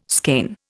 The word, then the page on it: skein: Indian